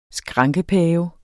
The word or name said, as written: skrankepave